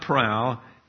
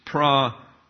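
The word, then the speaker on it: praupatheia: So it looks like prow, but it's not prow, it's pra-up-athia.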